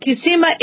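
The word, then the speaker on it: Qsymia: This is called Qsema. It's pronounced Qsema.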